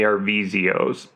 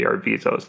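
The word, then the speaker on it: Arvizo: So they're the Arvizos. – Okay. – Some pronounce them the Arvizios, but it seems like it's mostly the Arvizos.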